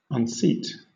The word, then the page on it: unseat: British English